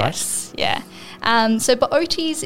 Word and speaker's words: Bootes: I'd probably steer you towards the constellation called Bootes. – Bootes? – Bootes, yeah. So it's, how is that spelt? – Oh, it's B-O-O-T-E-S, but you pronounce both O's, which is an odd one to say, definitely.